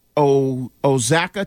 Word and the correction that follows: Oaxaca: cheese. – Oaxaca.